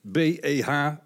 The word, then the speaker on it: BEH: BEH, uitgesproken als woord, Beh.